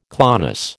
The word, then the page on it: clonus: American English